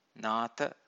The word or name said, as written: natha